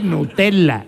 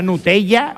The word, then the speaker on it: Nutella: ¿Por qué se dice Nutella y no se dice Nocilla? Eso no lo entendí yo nunca. – ¿Por qué? – No, la E, doble L, Nutella, Nocilla, ¿no? – Nocilla.